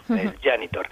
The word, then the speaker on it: Janitor: Y bueno, el cómic del que hablamos hoy se llama El Janitor, escrito Janitor, pero esta J que solo pronunciamos nosotros, fuera de España siempre es como Y, ¿no? El Janitor, que es una palabra inglesa que significa conserje, ¿no?